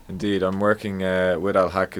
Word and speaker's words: Al-Haq: Am I pronouncing it right, Al-Haq? – Al-Haq, yes.